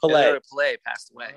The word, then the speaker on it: Pele: P-E-L-E just passed away. – Yeah. – How would you pronounce that? – I call him Pele.